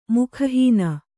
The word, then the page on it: ಮುಖಹೀನ: mukha hīna